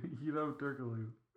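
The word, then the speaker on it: Hedo Türkoğlu: How do you say it? – Hito Turkoglu.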